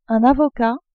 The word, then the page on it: avocat: French